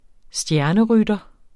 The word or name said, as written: stjernerytter